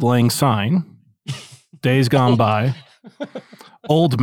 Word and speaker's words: Auld: And for clarification, Auld Lang Syne, the song, it's the first word is A-U-L-D, which is hilarious because that's also American Ultimate Disc League, but it's pronounced old. And the first choice is old as in O-L-D Lang Syne.